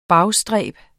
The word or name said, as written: bagstræb